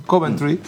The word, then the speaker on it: Coventry: Y, no sé si se pronuncia así, Coventry. – Sí, está bien.